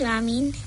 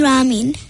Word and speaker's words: Rameen: My name is Ramin. – How do you say that? – My name is Ramin. – Ramin. And how do you spell Ramin? – R-A-M-E-E-N.